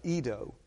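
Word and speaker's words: eido: And this particular one is the word E-I D-O, I think it's pronounced Edo. And it, I may be wrong on that pronunciation, but that's definitely how you spell it.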